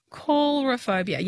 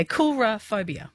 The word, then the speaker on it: coulrophobia: Well, C-O-U-L. I'm saying coulrophobia. Yeah, coulrophobia. – Yeah, like colour on air. Cool. Yeah, okay. Cool. Yeah. – Yeah, coulrophobia. Yeah, I'm saying it right.